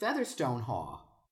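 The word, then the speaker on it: Featherstonehaugh: Now, again, I'm just curious, and I'm looking online as we're talking, and someone replied to someone saying, why is it pronounced Fanshawe? She said, in my family, it was always pronounced Featherstone Haw. Her parents were from North Cumbria, and she's saying that they did pronounce it Featherstone Haw.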